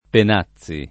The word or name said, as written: Penazzi